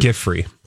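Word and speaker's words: Giuffre: Giffrey.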